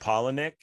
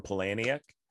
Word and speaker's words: Palahniuk: It's pronounced Palahniuk, but it aggravates hipsters if you call him Palahniuk.